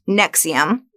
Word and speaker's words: NXIVM: First of all, it's pronounced NXIVM, like the heartburn medication.